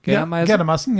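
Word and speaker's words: Geldermalsen: Weil wir waren, also man muss das so erklären, wir waren eingeladen von Napoleon in Geldermeisen, ist richtig ausgesprochen? – Geldermaßen, ja.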